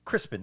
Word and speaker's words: Crispin: Crispin, that's how you say it.